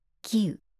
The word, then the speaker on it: 喜雨: 喜雨」